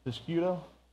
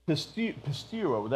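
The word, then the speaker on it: Pistuo (πιστεύω): Piscuto? No, that's, huh? Sure, okay, right. It's actually, I think I'm saying that right. Pistuo, that's right, there's no C in it.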